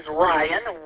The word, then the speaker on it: Ryan: Name is Ryan, Romeo Yankee Alpha November. I like to send it phonetically because people can hear Brian, which is not my name, but people hear it anyway.